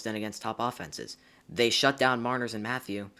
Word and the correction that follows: Matthews: Marner and Matthews, butchered that one.